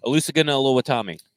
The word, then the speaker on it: Olusegun Oluwatimi: Olusegun Oluwatimi.